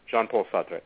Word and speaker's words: Jean-Paul Sartre: Jean-Paul Sartre.